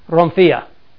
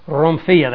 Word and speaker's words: rhomphaia: R-H-O-M-P-H-A-I-A. It would actually be pronounced, this is a rough breathing, Romphia. Has the kind of the trill of the R and the rough breathing, Romphia. That's why we put the H here.